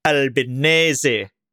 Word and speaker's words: Albanese: uh, I found a, a, very good video of him saying it's Albinese, as in as in spaghetti bolognese.